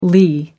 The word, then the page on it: lee: American English